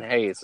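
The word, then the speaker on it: Reis: His name always messes me up. It's Wilson Hayes, it's pronounced.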